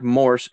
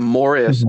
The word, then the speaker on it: Morse: He writes under the name Eric Morse, MORSE, not Morris as he pronounces it.